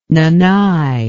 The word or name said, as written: Nanai